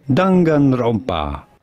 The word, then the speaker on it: Danganronpa: We pronounce, I don't know the actual name of it, but we call it a Dang Rapona. It's like Dang Ranapana or something. – Danganronpa.